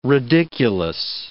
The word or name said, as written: ridiculous